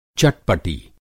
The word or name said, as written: चटपटी